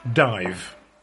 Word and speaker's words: Daimh: That D-A-I-M-H is apparently pronounced dive.